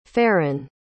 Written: farron

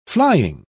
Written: flying

